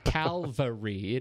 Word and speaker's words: Calvary: I think is it Cavalry or Calvary? – God, this is like nuclear. Well, I can't say that wrong. Different. Calvary. It is Calvary. I'm a dope. That'll get me in trouble. I'm getting email on that. Calvary. Thank you. Because it's not cavalry.